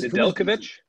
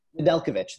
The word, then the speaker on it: Nedeljkovic: Who's Nedeljkovic? – Nedeljkovic. There we go. – Nedeljkovic. – You add the J there after the D, and it just completely messes me up.